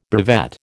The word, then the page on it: brevet: American English